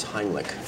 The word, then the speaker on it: Heimlich: Isn't that pronounced Heimlich? – I'm pretty sure it's Heimlich.